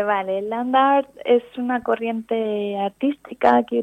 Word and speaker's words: Land Art: Land Art, sí.